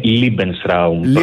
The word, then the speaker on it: Lebensraum: Lebensraum το έχουμε, είναι ακουστικά σε εμάς, αλλά η πιο σωστή προφορά είναι Lebensraum, προς τη γερμανική εκφορά του λόγου.